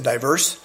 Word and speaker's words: divers: D-I-V-E-R-S. We would pronounce it diverse. He thought it said divers.